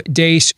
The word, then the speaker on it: Deace: Follow us on Twitter at Steve Dace Show. And the last name is spelled D-E-A-C-E.